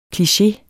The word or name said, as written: kliché